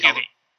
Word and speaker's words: Hubie: Hubie? Is that how you pronounce it? – Hubie. Rhymes with QB, as they freaking ran into you the whole time, because that's his nickname from Bullies.